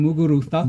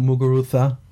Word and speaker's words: Muguruza: Oh, you mean Muguruza? – Muguruza.